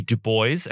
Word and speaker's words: Du Bois: On this day in legal history, February 23rd, we commemorate the birth of W.E.B. Du Bois, and that is how it is pronounced.